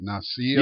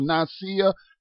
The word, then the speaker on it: Nicaea: Nicaea. – Yeah. – It's spelled Nicaea. N-I-C-A-E-A, if y'all want to look that up, the Council of Nicaea.